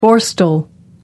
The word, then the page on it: borstal: American English